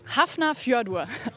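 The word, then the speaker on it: Hafnarfjörður: Das übertragen wir in der ARD live und wünschen jetzt heute Abend aber erstmal viel Erfolg im Europacup gegen Hafnar Fjordur aus Island. – Hast du souverän ausgesprochen, Christiane.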